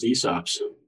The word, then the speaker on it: ESOPs: That's how it's pronounced, ESOPs.